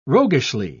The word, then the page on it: roguishly: British English